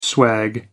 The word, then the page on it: swag: American English